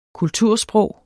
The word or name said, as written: kultursprog